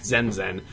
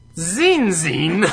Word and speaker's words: Zenzen: There's this kid last year, his name's Zach Zenzen. It sounds exactly like it's spelled, okay? And she goes, Zach Zinzin.